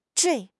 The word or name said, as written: 坠